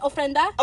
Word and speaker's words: ofrenda: Oh, here, tell this woman and her devil box my picture is on the ofrenda. Is that how it's pronounced? Ofrenda? – Ofrenda, yeah.